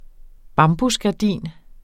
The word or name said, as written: bambusgardin